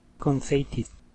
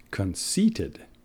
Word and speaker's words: conceited: Here is what you said. Conceited. Conceited. That word is pronounced conceited. Conceited. Quiere decir vanidoso.